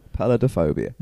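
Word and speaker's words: peladophobia: Pelodophobia.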